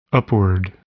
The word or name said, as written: upward